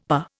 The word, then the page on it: per: Indian